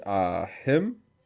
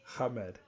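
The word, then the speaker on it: Ahmed: That stars Riz Ahim. Hamed.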